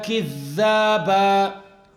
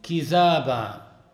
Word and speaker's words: كذابا: We can recite this ayah as لا يسمعون فيها لغوا ولا كذابا, with stress on the dal. And also it was narrated that Ali recited kizaba.